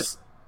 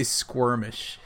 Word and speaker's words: skirmish: I never say it right. Got into skirmish. I always say squirmish.